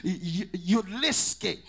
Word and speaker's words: Jedlica: It's spelled J-E-D-L-I-C-A, but it's pronounced Yudlicki.